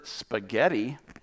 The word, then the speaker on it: spaghetti: I did win the spelling bee in the school with the word spaghetti. The silent H could trip you up if you're in eighth grade.